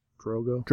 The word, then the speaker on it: Khal Drogo: Because I got the notification that my Khal Drogo, did I say that right? – Yeah, Khal Drogo.